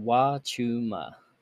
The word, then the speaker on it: Huachuma: Huachuma. It's pronounced Wachuma.